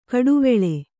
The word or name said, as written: ಘಟ್ಟಿವಳ